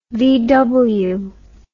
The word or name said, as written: VW